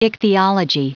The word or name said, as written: ichthyology